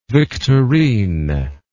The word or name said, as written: victorine